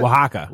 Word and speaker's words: Oaxaca: In Oaxaca. Is that how you say that? – Oaxaca.